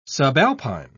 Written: subalpine

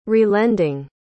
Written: relending

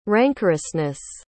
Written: rancorousness

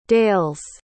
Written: dale's

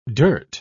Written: dirt

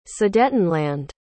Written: sudetenland

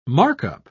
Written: markup

